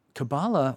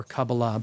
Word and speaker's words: Kabbalah: Um, Kabbalah or Kabbalah, both pronunciations are correct,